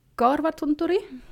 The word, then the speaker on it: Korvatunturi: Und genauer gesagt mal gucken, ob ich das jetzt richtig ausspreche mit Finnisch, Korvatunturi. – Sehr schön. – Ha. Ja, es gibt da auch das bekannte Santa Claus Village, das man das ganze Jahr über besuchen kann. Das liegt allerdings nicht am Korvatunturi.